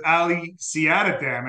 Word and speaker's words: Ali Siadatan: I am joined again once again with Ali Siadatan.